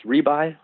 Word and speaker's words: Rebuy: Hi, I'm Randy Rebuy. Last name is pronounced Rebuy, like you buy something, you really like it, so you go and rebuy it.